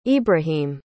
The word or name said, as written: ibrahim